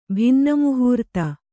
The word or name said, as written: ಭಿನ್ನಮುಹೂರ್ತ